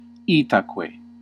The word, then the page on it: donc: [dɔ̃k]; France